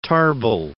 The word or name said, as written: Tarbell